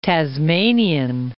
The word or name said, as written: Tasmanian